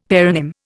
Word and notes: paronym: Indian

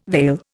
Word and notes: veil: Indian

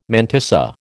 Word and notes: mantissa: American English

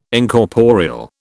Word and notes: Incorporeal: British English

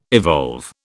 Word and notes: evolve: British English

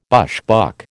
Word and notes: bushbuck: American English

